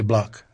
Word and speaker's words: Bloch: Por cierto, su apellido se deletrea B grande L O C H. René Bloch, así lo conocíamos. – Y así, así, así lo pronunciaban también.